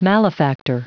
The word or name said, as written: malefactor